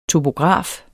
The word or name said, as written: topograf